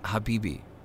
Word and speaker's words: Habibi: Speaking of, you and your Hubba Bubba still on break? – It's pronounced Habibi.